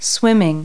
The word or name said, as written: swimming